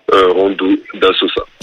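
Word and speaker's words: Rondodasosa: C'était du coup la musique Playa de du coup de comment tu prononces déjà Rondo Sosa. J'arrive pas à le prononcer. – Rondo da Sosa.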